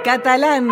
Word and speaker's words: Catalán: Catalán, Catalán, ese acento en la segunda A.